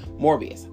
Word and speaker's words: Morbius: I would not want, or Morbius, I'm sorry, I keep saying his wrong name wrong. Morbius.